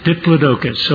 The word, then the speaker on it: Diplodocus: The dinosaur described in Job, they say, is probably a Diplodocus, and I'm not sure I pronounced that right. That's pretty close. Diplodocus.